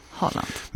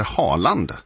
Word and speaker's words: Haaland: Jag tror att de tror att han heter Haaland. – Alltså att han heter det... – För det är ju det alla säger internationellt, för att han har två A. Men det uttalas ju Å.